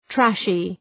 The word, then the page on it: trashy: ‘træʃı